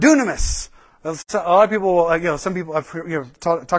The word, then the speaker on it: dunamis: This is where we get our word dynamite. Is dynamis as well. The way to pronounce it. So dynamis, dunamis, dynamite power.